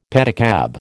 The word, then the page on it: pedicab: American English